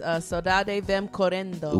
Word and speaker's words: Saudade Vem Correndo: And the song again is Saudade Vem Correndo. – Whoa, to say that again, that was kinda dope. You said that properly. – I didn't roll my R.